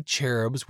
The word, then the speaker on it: cherub: The little baby cherubs with two wings are not at all like the true cherubs of God. In the Hebrew language, cherub, the ch pronounced as a k, is singular, and cherubim is plural.